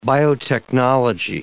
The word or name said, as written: biotechnology